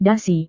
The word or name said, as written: dasi